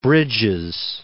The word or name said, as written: Bridges